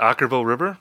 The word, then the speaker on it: Okkervil River: I think that's it, Okkervil River.